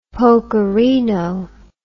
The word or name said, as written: pokerino